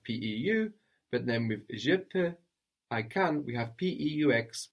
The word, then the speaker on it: peux: I can or I am able in French is je peux. – Je peux. – And this peux is spelled PEUX. We have that X, that silent X on the end there. What was a little? – Un peu.